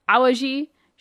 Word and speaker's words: Awaji: Awaji, Shikoku, Ikikyushu, Tsushima, and Honshu, which is the biggest island.